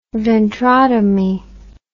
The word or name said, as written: ventrotomy